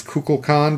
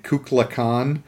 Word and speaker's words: Kukulkan: We went to the recording sessions, except we weren't at Shatner's, and he mispronounced a key word in the script, which is Kukulkan, because that's the Mayan god who's basically the alien. He kept saying it Kukulkan.